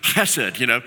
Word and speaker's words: hesed: You got to say it from the throat here. It's kind of like, hesed, And it means, steadfast love.